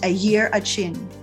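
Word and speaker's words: Arrernte: Phonetically, it is pronounced a year a chin.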